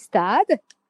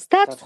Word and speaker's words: Stade Français: So you English people tend to mention Stade Français Paris. Stade Français. The correct pronunciation is Stade. Repeat after me. Stade.